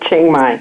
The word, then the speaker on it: Chiang Mai: You're in a part of Thailand I can't pronounce, Chiang Mai, I believe, if I say it. – Chiang Mai, that's right.